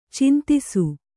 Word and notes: ಛಂದಸ್ಸು: chandassu

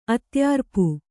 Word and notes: ಆದೇಯರೂಪ: ādēyarūpa

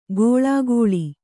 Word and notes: ಘೋಳಾಘೋಳಿ: Kannada

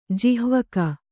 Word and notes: ಜಿಹ್ವಕ: jihvaka; Kannada

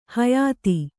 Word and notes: ಹೊಯ್ತ: kannada

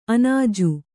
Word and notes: ಅಣ್ಣಾಜಿ: aṇṇāji

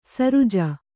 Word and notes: ಶರಜಾ: śarajā

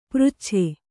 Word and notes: ಪೃಚ್ಛೆ: přcche

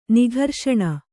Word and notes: ನಿಘರ್ಷಣ: kannada; nigharṣaṇa

